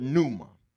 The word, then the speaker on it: pneuma: The word spirit in Greek is the word pneuma. Pneuma. Oh, y'all want me to spell it? Some of y'all, N-U-M-A. Okay. No. P-N-E-U-M-A. P-N-E-U-M-A.